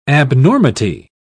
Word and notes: abnormity: British English

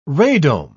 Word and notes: radome: British English

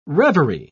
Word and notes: revery: British English

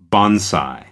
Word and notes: bonsai: American English

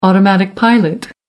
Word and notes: automatic pilot: American English

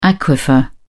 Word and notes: aquifer: British English

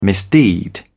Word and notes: misdeed: British English

